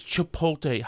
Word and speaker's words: Chipotle: No, no, it's Chipotle, Howie.